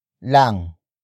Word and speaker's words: lang: Ikaw is spelled as I-K-A-W. It means you in focus form. The next word is lang. Lang. Lang. Lang is spelled as L-A-N-G.